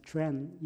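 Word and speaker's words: quan (权): Usually, in official document too, the word or character used to translate the Western idea of a citizen's right is called the quan, Q-U-A-N, pronounced as quan in Mandarin Chinese.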